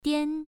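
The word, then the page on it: dian: tone 1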